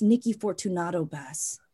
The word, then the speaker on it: Nikki Fortunato-Bass: It's Nikki Fortunato-Bass. – Was I mispronouncing it like? Oh, I thank you. Nikki Fortunato. Okay.